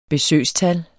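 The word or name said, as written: besøgstal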